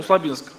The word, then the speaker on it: Усть-Лабинск: И вот мы ехали и подъехали к городу Белореченску, или Кусть-Лабинску. И там есть мост. И вот обычно на этом мосту мы проезжаем быстро, так, хорошо. Правильно говорю, да? Да. Услабинск, да.